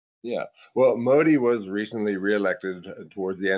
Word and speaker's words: Modi: Modi.